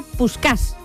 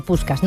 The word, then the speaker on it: Puskás: No es de extrañar que al final se le terminase apodando Puskás, que en realidad al principio era Puskás, con el acento en la A, ¿no? En húngaro. Significa escopeta en su idioma, en húngaro, Puskás, ¿no?